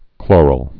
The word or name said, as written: chloral